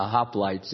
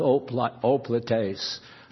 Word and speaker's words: hoplites: I was telling Tony about the hoplites. He says it's pronounced oplites, the Greek civic guard and all.